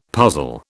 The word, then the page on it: puzzle: British English